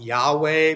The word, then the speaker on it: Yahweh: But in English, they transliterate to YHWH, which the vast majority of scholars say that that's pronounced Yahweh based on studies of the language and words that make up it.